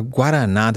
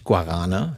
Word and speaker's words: Guaraná: Ja, äh, Guaraná, das hast du so schön gesagt, äh, und nicht Guarana, so wird mhm. Guaraná ausgesprochen in Brasilien, äh, ist eine Frucht, und so eine kleine äh, äh, äh, rote Bohne, und die kommt aus dem Amazonas, wächst in Büscheln.